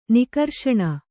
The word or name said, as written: ನಿಘರ್ಷಣ